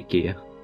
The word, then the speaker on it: IKEA: Okay, IKEA. Watch and be amazed. – Which to me always sounds Japanese. – It really does. – But I am assured by Swedish people that's how it's pronounced.